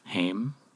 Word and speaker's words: Haim: And she's like, she does the same for Haim. Is that how you pronounce that? – Haim?